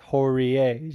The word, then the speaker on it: Horie: It's Hori, but I guess it's Horier, as you pronounce both vowels.